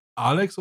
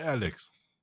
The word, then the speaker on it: Alex: Alex oder Alex? Ja, das ist also eigentlich amerikanische, also englische Aussprache tatsächlich.